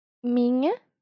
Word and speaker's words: minha: La palabra miña se escribe M-I-N-H-A. Pero ese sonido de n-h vas a leer como si fuera un ñ, como en niña.